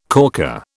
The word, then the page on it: corker: British English